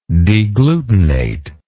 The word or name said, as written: deglutinate